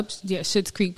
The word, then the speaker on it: Schitt's Creek: Schitt's Creek? – Yeah, Schitt's Creek, but it's spelled S-C. – Yeah, S-C-H.